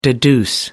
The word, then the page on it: deduce: American English